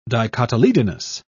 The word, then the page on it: dicotyledonous: British English